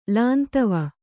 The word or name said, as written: ಲಾಂತವ